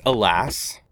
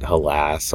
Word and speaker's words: Helas: Yeah, people pronounce it halas or helas or whatever, but it's alas.